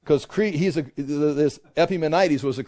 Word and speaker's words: Epimenides: And the prophet who says this is Epimenides. Epimenides. There, that's how you say it.